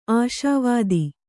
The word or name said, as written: ಆಸ್ವಾದ